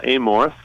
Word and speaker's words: Amorth: As we said, you're the marketing and sales director, but it was written by Father, how do you say his last name? Amos? – So his name is Father Gabriel Amorth.